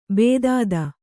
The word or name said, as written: ಭೇದಿತ